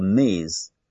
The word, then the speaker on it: Ms.: 啊，所以我们通常不太了解对方是否已婚或者是未婚，那这个时候我们可以用什么呢？Ms. 就是个 M 后面一个 S，读音读作 Ms. 那么可以直呼他的名，比如我知道他姓李，我说 Ms. Lee，就是李女士啊，就称为女士或者 Madam 啊，Madam。